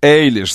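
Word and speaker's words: Eilish: Билли Эйлиш, запомните, Эйлиш, как Эллис. Помните, вот она пишется Эллиш, только та была Эллис, а это Эллиш.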